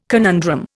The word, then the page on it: conundrum: Indian